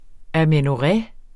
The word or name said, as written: amenorré